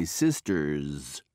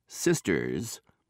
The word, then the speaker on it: sister's: Notice how the last S in the underlined word is pronounced. He's my sister's son. It makes a zzz sound. The words sisters, boys, and moms all make a zzz sound.